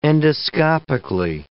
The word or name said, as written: endoscopically